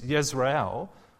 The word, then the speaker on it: Jezreel: See, in Hebrew, when you say a J, you say it like a Y. So in Hebrew, it reads Yezreel, which sounds very much like Israel.